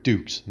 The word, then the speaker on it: Dux: Jean-Claude in Bloodsport, a kickboxer, I can't remember, plays Frank Dukes. And that's also Put Up Your Dukes, even though it's spelled D-U-X.